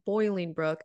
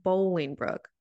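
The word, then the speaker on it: Bolingbrook: The city is not Boiling Brook. It is pronounced Bowling Brook.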